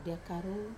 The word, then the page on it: dia karu: Wamesa